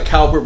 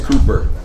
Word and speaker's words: Cowper: It looks like Cowper, but it's pronounced Cooper, from what I understand.